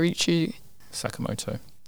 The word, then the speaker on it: Ryuichi Sakamoto: Monster features the final score of Japanese composer Ryuichi Sakamoto.